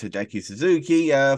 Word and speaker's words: Hideki Suzuki: I know it's Hideki Suzuki, former NXT trainer, of course.